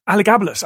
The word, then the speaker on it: Elagabalus: Elagabalus.